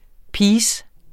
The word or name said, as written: piece